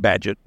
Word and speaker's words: Bagehot: There was a gentleman in the 1880s that they founded The Economist magazine, and his name was Walter Badgett. It's spelled B-A-G-E-H-O-T, but the British pronounce it Badgett.